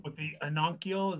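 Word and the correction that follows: Ovangkol: Ovenkoll, like Ovenkoll or Bubinga.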